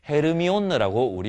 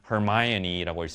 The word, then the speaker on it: Hermione: 누구의 역할이냐 하면 of Hermione, 헤르미온느라고 우리말로는 읽었지만 Hermione라고 읽습니다.